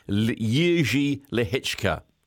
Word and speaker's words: Jiří Lehečka: Now, it's spelled J-I-R-I-L-E-H-E-C-K-A, okay? But you say it, Jiří Lehečka, okay? So it's, but it looks like Jiří Lehečka.